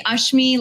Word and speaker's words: Ashmi: It's actually Ashmi, like Usher.